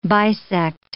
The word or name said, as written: bisect